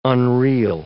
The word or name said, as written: unreel